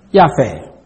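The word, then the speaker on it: Yafeh (יפה): The word lovely comes from the Hebrew word yafeh. Yafeh. It's spelled Y-A-P-H-E-H, Yafeh.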